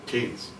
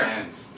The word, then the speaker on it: Cannes: Cannes. – Where? – Cannes.